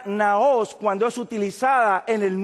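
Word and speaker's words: naos (ναός): Así como usted lo encuentra pronunciado, naos, N-A-O-S.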